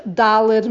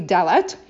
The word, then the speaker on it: Dalet: Okay, it's really like Dalet. Even though we say Dalet, it's really Dalet.